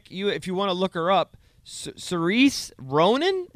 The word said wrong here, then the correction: Saoirse: Is that her name? – Saoirse. – Is that how you say it? Saoirse Ronan.